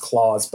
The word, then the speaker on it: Klaus: People have assured me it's supposed to be pronounced Claus, but it's spelled Klaus.